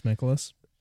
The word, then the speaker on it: Mikolas: Is it Mikolas? That's how you pronounce it? – Mikolas? Yeah, Miles Mikolas.